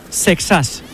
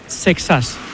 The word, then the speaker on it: Seixas: Exactamente os lo voy a decir. Lo que pasa es que luego cada uno lo dirá como quiera, pero es Seixas. Paul Seixas. Así se pronuncia, Seixas.